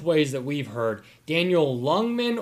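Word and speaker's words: Ljungman: We've heard it both ways. Joe Migdanell said it Lungman, but looking up the pronunciation in Swedish on Google, it was Youngman.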